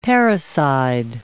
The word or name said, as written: parricide